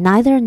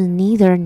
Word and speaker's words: neither: 那在这边呢我会选择英式发音 neither nor, 而不是 neither nor 的原因呢是因为我觉得小王子呢他是一个有点就是我觉得《小王子》这本书呢，就是他那个作者，毕竟他是法国的贵族后裔。